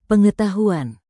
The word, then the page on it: pengetahuan: indonesian